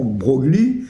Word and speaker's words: Broglie: Alors, De Broglie, je ne l'ai pas mis là, j'aurais dû le mettre, j'ai mis De Broglie, évidemment, Broglie, c'est le mot, enfin ça vient de l'Italie, c'est pour ça qu'on prononce Broglie.